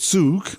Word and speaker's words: Zug: My confusion at the word Zug, or Zug, as I first mispronounced it,